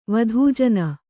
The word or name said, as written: ವಧೂಜನ